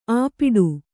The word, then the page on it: ಆಪಿಡು: āpiḍu